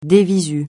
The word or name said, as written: de visu